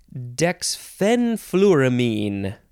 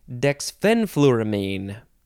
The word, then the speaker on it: Dexfenfluramine: Dexfenfluramine. The second syllable is emphasized. Dexfenfluramine.